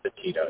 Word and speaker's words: Petito: We're going to, just a preference, we're going to talk about two really tough cases, Alex Murdaugh and then the Gabby Petito? Petito, is that how you say it? – I believe it's Petito, yes.